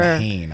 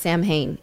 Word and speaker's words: Samhain: It's spelled like S-A-M-H-A-I-N. See it in the upper. – Samhain, I see. Okay. – Right, but it's not pronounced Samhain. It's Samhain.